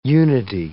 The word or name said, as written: unity